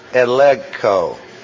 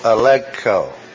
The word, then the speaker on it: elegko: The word convict is the verb elegko. E-L-E-G-K-O, elegko. Elegko, accent on the second syllable, elegko.